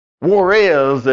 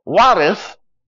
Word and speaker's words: Juarez: My first trip to West Texas, we were going down to Juarez, as you pronounced it in Fort Worth, or Juarez after you got there.